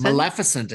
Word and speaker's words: Maleficent: Maleficent is how we pronounce that name, Tristan.